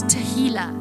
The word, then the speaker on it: tehillah: And the last one is tehillah, which rhymes with tequila.